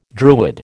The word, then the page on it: Druid: American English